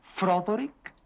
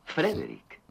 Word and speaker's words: Frederick: ¿Dice usted también Froderick? No, Frederick. ¿Y por qué no es Froderick Frankenstein?